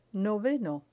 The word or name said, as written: noveno